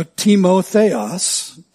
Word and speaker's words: Timotheos: If you're taking notes, it's spelled in the Greek, uh, in the transliteration from the Greek, just the same way we spell the word time, T-I-M-A, but it's pronounced time.